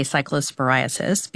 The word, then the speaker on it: cyclosporiasis: If you are worried, there is a CDC cyclosporiasis, I didn't pronounce that right, outbreak. – Cyclosporiasis. – Yes.